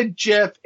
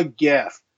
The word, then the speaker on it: GIF: It's not a GIF. It's a GIF. No, no, it's a GIF. It's a soft G.